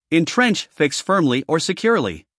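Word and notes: entrench: uk /ɪnˈtrentʃ/; en-us /ɪnˈtrentʃ/

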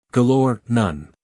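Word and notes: galore: uk ɡəˈlɔː(r); en-us ɡəˈlɔːr

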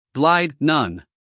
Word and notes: glide: ɡlaɪd

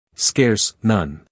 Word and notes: scarce: skeəs; skers

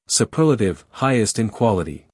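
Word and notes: superlative: uk suːˈpɜːlətɪv; en-us suːˈpɜːrlətɪv

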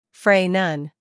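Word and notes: fray: uk freɪ; en-us freɪ

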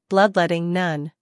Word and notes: bloodletting: ˈblʌdletɪŋ

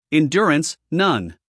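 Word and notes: endurance: uk ɪnˈdjʊərəns; en-us ɪnˈdʊrəns

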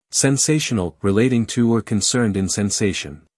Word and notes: sensational: senˈseɪʃən(ə)l

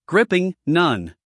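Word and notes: gripping: uk ˈɡrɪpɪŋ; en-us ˈɡrɪpɪŋ

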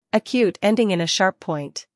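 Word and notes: acute: uk /əˈkjuːt/; en-us /əˈkjuːt/

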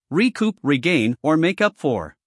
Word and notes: recoup: rɪˈkuːp

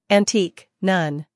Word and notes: antique: ænˈtiːk

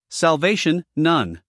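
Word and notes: salvation: uk /sælˈveɪʃ(ə)n/; en-us /sælˈveɪʃ(ə)n/

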